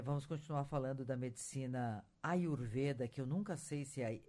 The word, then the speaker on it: Ayurveda: É Ayurveda. – Ayurveda falou mesmo. – Isso mesmo. – Eu nunca sei se é o E aberto ou é fechado, então é Ayurveda.